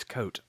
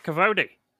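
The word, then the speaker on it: Kvothe: I'm like, okay, his name's Coat. But I had read the description beforehand, and it was like K-V-O-T-E. So I'm like, thank God someone pronounced this for me, because I'm going to be around going, I like Cavote. Cavote's a good character.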